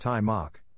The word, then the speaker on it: Taimak: His name, pronounced Taimak, was given to him by his father.